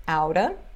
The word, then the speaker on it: out of: E a pronúncia é outa, sorta e kinda.